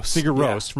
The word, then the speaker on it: Sigur Rós: Sigur Rós from Iceland.